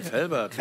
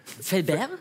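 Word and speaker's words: Velbert: Es gibt aber so viele Leute sprechen das falsch aus, weil wir auf der anderen Rheinseite sind, die denken, das ist schon so weit an Frankreich, dass man das... – Felbert? – Ja. Ja. So viele Leute wollen das immer so aussprechen, deswegen, ja, du hast das genau richtig ausgesprochen.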